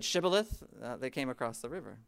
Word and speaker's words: shibboleth: and the pronunciation of it was shibboleth. But the Ephraimites in their dialect, they didn't have that SH sound. They could only say sibboleth.